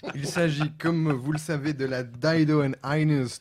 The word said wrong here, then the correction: Dido and Aeneas: The Person. – Oh! Oh, ça fait mal prononcer comme ça. – On s'en fout, c'est pas grave. Je vais couper au moins. – Dido and Aeneas.